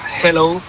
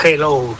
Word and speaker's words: hello: Y la segunda, dile a Elena que su dicción en castellano es la más perfecta que conozco, pero que cuando diga hello, que no pronuncie la J, que no es hello, que es hello, que la aspire la J y no pronuncie la J potente castellana.